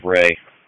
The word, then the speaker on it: Rhea: It's going to be on Brock Rhea. I'm sorry, pronounced Ray. R-H-E-A.